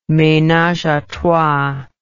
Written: menage a trois

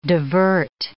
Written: divert

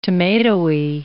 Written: tomatoey

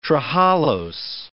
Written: trehalose

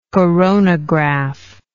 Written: coronographic

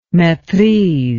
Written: maitrise

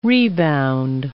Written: rebound

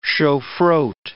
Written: shofroth

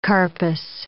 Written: carpus

